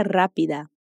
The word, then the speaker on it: rápida: Repite, pero sea rápida. Señorita. Pero significa más en portugués. Y la palabra rápida se pronuncia con el sonido semejante al de la R de arar.